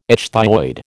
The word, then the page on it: ichthyoid: American English